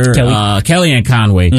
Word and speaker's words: Kellyanne Conway: Kathy Ann Conway, I think I got it correctly, Trump's old campaign manager, Kellyanne Conway, got in contact with him.